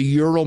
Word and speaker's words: Ural: But the basis for this book actually came out of an incident that happened in Russia in 1955 in the Ural. Am I pronouncing it right? The Ural Mountains? – Yes.